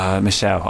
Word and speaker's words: Michel: It's Michelle. I know it's Michelle.